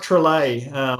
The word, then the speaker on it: Trolle: pronounced Trelay. It's got an accent over the E like Bolle and Nestle, which is how I usually pronounce it to people.